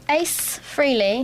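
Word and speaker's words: Frehley: Ace Freely, is that how you say his name? – Yeah.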